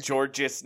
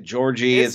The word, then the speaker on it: Georges: Am I saying it wrong? Is it Georgie? – George, dude. People just say George.